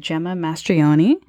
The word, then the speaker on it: Gemma Mastroianni: I'm here with Gemma Mastroianni.